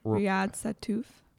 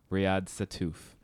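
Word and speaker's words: Riad Sattouf: Riyad Satouf. – Riyad Satouf.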